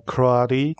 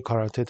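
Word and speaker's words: karate: و محضر شما بزرگواران عرض کنم که کراری تلفظ درسته هستش، حواستون باشه که کاراته تلفظ نکنید.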